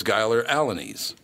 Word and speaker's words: Giler Alanese: So it's Giler Alanese. So it's just exactly what it looks like.